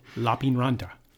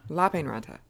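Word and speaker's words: Lappeenranta: Lapinranta. – That's not how you say it. – Lapinranta. – Lapinranta. – Lapinranta. – In Finnish, the emphasis is on the first syllable.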